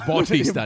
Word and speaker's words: Bautista: Look at Batista. He's definitely on the roids still. – How dare you? Dave Bautista. – Bautista, Nick. Bautista. B-A-U, not the, how you spell it.